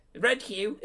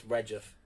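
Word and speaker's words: Redheugh: Red hue. – It's not red hue. – That's how it's spelled. – But that's not how it's pronounced. It's Redgiff.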